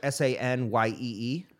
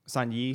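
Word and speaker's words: saignée: I believe phonetically, it'd be like Sanyee. – Probably. So Saunier. Saunier.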